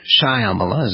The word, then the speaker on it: Shyamala: And her mother, Shyamala Gopalan, her first name is pronounced Shyamala, as I said, was an Ayur Brahmin, the pinnacle of the caste structure of her native Tamil Nadu, a state in South India.